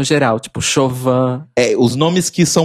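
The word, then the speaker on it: Siobhan: E Chauvin, que escreve Cioban, mas é Chauvin. – Sim. – E tem Nymph também, que se escreve N-I-A-M-H.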